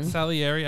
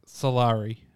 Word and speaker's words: Salieri: Salieri, I almost wanted to read it like Solari. I almost read it Solari.